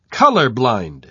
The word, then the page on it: color-blind: British English